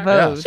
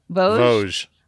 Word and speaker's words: Vosges: Vosges. Yeah. Check. Say it again. Vosges. – Vosges.